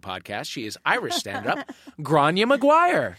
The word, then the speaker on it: Gráinne: Is it G-R-A-I-N-N-E? – Exactly. – Yes, it is. – It's beautiful. – Wow. – Exactly how it's pronounced.